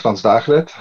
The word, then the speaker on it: Frans Dagelet: My name is Frans Dagelet.